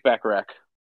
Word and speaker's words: Baccarac: I noticed you didn't like mention any travel rides, and you said almost 2 years ago now you got down to go, is it Bacharach? Is that how you say it? – Yes, Lake Bacharach.